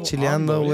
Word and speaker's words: chileando: You got to say it like the way everything's ando, bro. – Chileando way. Yeah, chilando, it's not even a real word, but chileando is how you would say it.